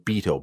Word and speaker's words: Beato: Rick Beato, B-E-A-T-O. Corrected my pronunciation on the guy who has the YouTube videos, Beato, not Beato, Beato.